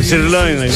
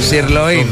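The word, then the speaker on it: sirloin: Πώς προφέρεται, έλα? – Σιρλόιν. – Σιρλόιν.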